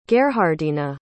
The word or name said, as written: gerhardina